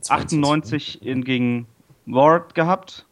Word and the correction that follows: Wrath: Oder spricht man das Ward aus? – Wrath. – Wrath. – Wrath.